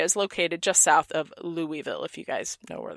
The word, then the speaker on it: Louisville: You have to say Louisville. Like Nashville. – Louisville. – No, there's no ville. It's a vol. Louisville. – Louisville. – Nashville. – So you say it without opening your mouth. Louisville. – It rhymes with full.